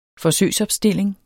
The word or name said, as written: forsøgsopstilling